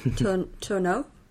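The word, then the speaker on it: Tourneur: Yeah, I'd love to get more Tournier, and is that how you say it? – Tourneau.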